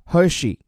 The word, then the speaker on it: Hershey: 首先，这家巧克力品牌了解一下 Hershey。发音的时候，这个 S H E Y 发作西，这是美国两大巧克力公司之一啊。另外一家是拥有 M M 豆的马氏，而这家 Hershey's 中文翻译叫好时，他家的当家产品很多人也不陌生，就是这个 Kisses。